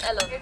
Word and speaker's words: Ellen: Enchantée, je m'appelle Ellen. – Allen. – Ellen. C'est Ellen en français, mais je préfère Ellen.